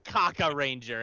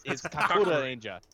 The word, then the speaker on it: Kakuranger: Oh, is it Japanese pronunciation? – Yes, that is the Japanese. Oh, so it's not Kaka Ranger. It's Kakura Ranger.